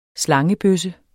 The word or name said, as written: slangebøsse